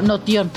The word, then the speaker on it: Notion: Como Notion, N-O-T-I-O-N. Como Notion. Notion.